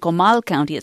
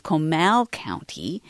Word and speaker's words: Comal County: County isn't Comal County, it's Comal County.